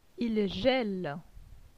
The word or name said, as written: Il gele